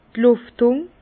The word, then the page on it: d' Luftung: Bas-Rhin; Herrlisheim